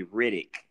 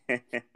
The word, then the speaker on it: Reddick: Rufus Reddick, R-E-D-D. Everybody always calls me Riddick, but no. It's Reddick.